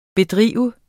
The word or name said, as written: bedrive